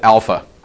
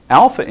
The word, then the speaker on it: alpha: So we start out with alpha, and the A sound is in father.